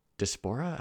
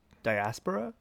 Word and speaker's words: diaspora: But generally, it's just from the diaspora. How do you say that word? – Diaspora? – Diaspora. Thank you.